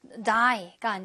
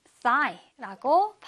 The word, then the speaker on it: thigh: 살짝 윗니와 아랫니 사이에 혀를 좀 넣어야 돼요. 그래서 three라고 발음하는 고 발음으로 해주시면 돼요. 허벅지인데 thigh, 그냥 die가 아니라 thigh라고 발음을 해주시면 되겠습니다.